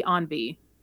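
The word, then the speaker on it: EN-V: So riders pass by two concept cars, the Chevy Miray and the Chevy Envy. Envy? Envy. I don't know. How do you spell that? – I think it's Envy. It's just E N dash V.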